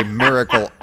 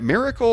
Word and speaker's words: miracle: Miracle or miracle? It's supposed to rhyme with mirror.